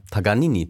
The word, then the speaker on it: Paganini: Ja, Paganini, Paganini.